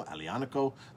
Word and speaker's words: Aglianico: It's such a powerful, bold wine made from Aglianico, A-G-L-I-N-A-C-O, Aglianico. The G is silent.